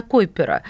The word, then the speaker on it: Койпера: Кстати, вот я всегда ставлю ударение на Койпера. – Все правильно.